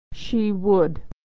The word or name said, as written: she would